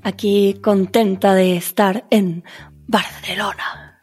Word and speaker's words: Barcelona: La C en Barcelona te ha salido, pero como a una nativa.